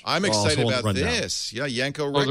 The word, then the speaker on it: Yanko: Yanko.